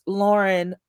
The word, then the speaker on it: Lauren: American-founded brand Ralph Lauren, it's pronounced Lauren, by the way, but Ralph Lauren is finally recognizing the influence of Black American culture in its advertising.